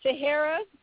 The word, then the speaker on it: Tihirah: Yes, you can support me at Tahara on Twitter. Facebook is Tahara Talaferro. Instagram is Actor Tahara. And my name is spelled T-I-H-I-R-A-H.